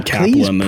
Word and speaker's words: Kaplan: C-A-P-L-A-N. I keep saying Chaplin. Like, I used to say Chaplin all the time, but I had to look it up for this because I was like, I need to know for sure how to say her name, but it's actually Kaplan.